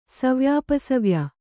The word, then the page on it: ಸವ್ಯಾಪಸವ್ಯ: savyāpasavya